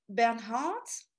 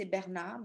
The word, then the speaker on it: Bernhardt: Alors, the last one is Sarah Bernhardt. So I know in English you say Bernhard, but in French we say Bernard because we don't pronounce the H.